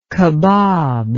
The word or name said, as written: kabab